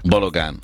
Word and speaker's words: Balogun: Balogun, hogy kell kiejteni? Mert valaki Balogunnak mondja, valaki Balogánnak. – Balogánnak mondja, hát Balog Balázs megkérdezed, ő Balogán, ő meg tudja, Balogbán meg tudja mondani. – Laciéke Balogánnak mondták.